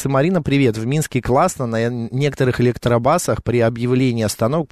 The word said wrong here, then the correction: электробус: Почему я электробас говорю? Электробусах, да? – Ты же учишь английский, поэтому у тебя бас.